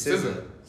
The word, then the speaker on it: SZA: It's SZA.